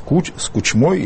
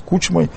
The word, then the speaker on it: Кучмой: и вместе с Кучмой или с Кучмой, по-разному ставят ударение.